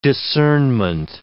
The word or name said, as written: discernment